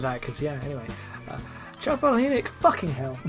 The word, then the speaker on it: Palahniuk: Palahniuk. I'm putting an emphasis on the H when the H isn't meant to have any emphasis whatsoever. I've been saying like Palahniuk all these years, and I apologise.